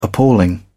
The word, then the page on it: appalling: British English